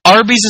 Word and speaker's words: Arby's: Arby's is named after roast beef. RB spells the letters R and B, which is the first letter in roast beef, and it's pronounced Arby.